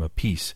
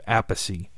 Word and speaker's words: Appice: A lot of people butchered the pronunciation of Carmine's last name, so he just decided to go with it. Uh, so he pronounces his last name a piece. Vinny came along later with uh, primarily Black Sabbath, as many of you recall, and he went with the traditional original pronunciation of Appice. So uh, it is Carmine Appice, Vinny Appice.